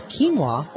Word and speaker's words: quinoa: Dinner must include a vegetarian option, plus quinoa or couscous. – You know what? I am absolutely stunned that you pronounce quinoa correctly.